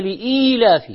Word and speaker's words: لإيلاف: هذا اذا نطقها صح هو يمكن يقول لإيلاف قريش، وهي لإيلاف فيها ياء لام همزة ياء،